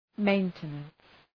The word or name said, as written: maintenance